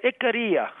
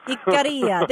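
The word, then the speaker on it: Ikaria: Tell me how to pronounce it one more time. – Yeah, it's musical. Icaria. – Icaria.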